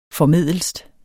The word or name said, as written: formedelst